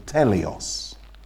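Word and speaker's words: teleios: The Greek word, which is translated into English as perfect, is from a Greek word that is pronounced somewhere in the region of teleos.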